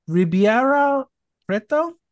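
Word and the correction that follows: Ribeirão Preto: Ribeirão. Preto.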